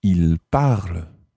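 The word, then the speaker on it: ils parlent: It's il parle, and that's spelled P-A-R-L-E-N-T.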